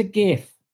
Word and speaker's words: GIF: It's a GIF. It's a hard G.